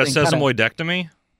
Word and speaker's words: sesamoidectomy: No, I cannot pronounce that one. – Yeah. – Are you talking about a sesamoidectomy? – There you go.